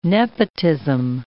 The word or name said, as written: nepotism